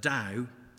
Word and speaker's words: Tao: And the Tao is, which is spelled T-A-O, is pronounced D-A-O, as I found out from a very polite audience when I was giving this talk in Nanjing.